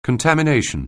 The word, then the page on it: contamination: anglais